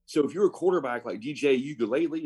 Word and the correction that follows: Uiagalelei: is that how you say it? – No. – Okay. – No. Uiagalelei.